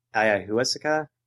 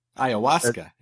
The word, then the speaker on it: ayahuasca: But I guess police also said that the ringleader of the group was last seen traveling to Peru to buy this thing called ayahuasca. I'm not pronouncing that correctly. – Ayahuasca.